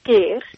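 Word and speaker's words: Skyr: It would probably say skeer, you would might want to pronounce it, but it's actually pronounced skeer.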